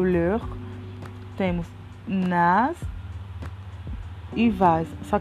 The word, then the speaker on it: leur: Ele tem um S no final da escrita, só que não pronuncia, então acaba ficando igual ao singular. Leur.